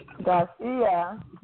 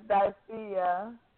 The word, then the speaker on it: Dyphia: Dyphia. – Dyphia? – Yes, Dyphia.